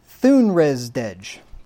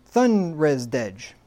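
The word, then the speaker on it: Þunresdæg: So anyway, it's pronounced Thunresdej. Thunresdej, meaning Thunner's Day.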